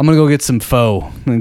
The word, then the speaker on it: pho: It's pronounced pho. It's spelled pho.